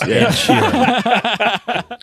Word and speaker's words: Ed Sheeran: Chef says you mispronounced Ed Sheeran, John. It's Ed Sheeran.